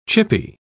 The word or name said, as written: chippy